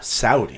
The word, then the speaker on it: Saudi: Saudi. – What does that mean? – That's me saying it correctly.